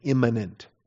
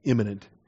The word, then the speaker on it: immanent: He is transcendent and immanent. Got to be careful how I pronounce that, otherwise it sounds like imminent in the imminent rapture of the church or something like that.